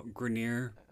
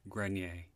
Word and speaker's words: Grenier: First of all, Grenier. Is not the way the name is pronounced. It's French. It's pronounced Grenier.